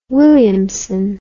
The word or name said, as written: Williamson